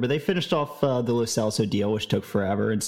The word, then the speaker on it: Lo Celso: it is pronounced Lo Celso.